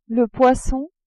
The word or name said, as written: poisson